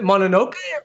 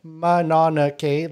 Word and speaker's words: Mononoke: Yeah, from what I know of like Japanese anime, because I used to watch a lot when I was younger, usually their pronunciation is very stuttered. Like each syllable gets like equal relevance. So I think Mononoke is a little bit more of the pronunciation. – Western? – No, like Mononoke, like each syllable. – Each one is same. Okay. – Yeah, whereas if you say Mononoke, you're giving more emphasis on the noke.